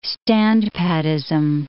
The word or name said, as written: standpattism